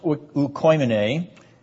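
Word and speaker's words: oikoimene (οἰκουμένη): It's pronounced oikoimene, and it's a word from which we get the English word ecumenical, and it means world, as Luke has used it in this first verse of chapter 2.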